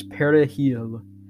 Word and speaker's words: perejil: See, in Spanish, the term for parsley is perejil. However, in French and Haitian Creole, the term is pronounced with a voice velar fricative, so it sounds more like perejil.